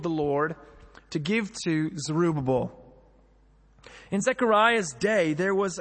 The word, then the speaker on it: Zerubbabel: So he said to me, "This is the word of the Lord to Zerubbabel." That's how you say it properly. That's the American way of saying it properly.